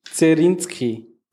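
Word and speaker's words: Dserschinski: Felix Edmundowitsch Zerinski. Schön ausgesprochen.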